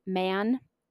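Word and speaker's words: Mahon: I am going to use the pronunciation Man because I think that's just simpler for me as I tell the story.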